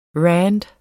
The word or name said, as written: rand